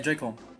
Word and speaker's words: Joy-Con: É, Joy-Con.